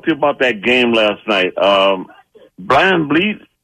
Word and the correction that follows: Van Vliet: that's how you pronounce his name? – Van Vliet. V-L-E-E-T. Van Vliet.